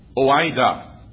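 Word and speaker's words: oida (οἶδα): And that word is spelled, according to some commentators, oida. Let me spell that one for you. O-I-D-A. O-I-D-A.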